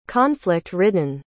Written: conflict-ridden

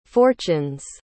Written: fortunes

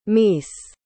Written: meece

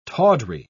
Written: tawdry

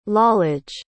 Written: Lalage

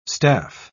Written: staff

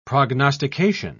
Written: prognostication

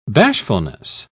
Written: bashfulness

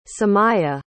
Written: samiyah

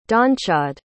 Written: donnchadh

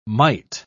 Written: might

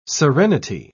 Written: serenity

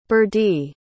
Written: berdie

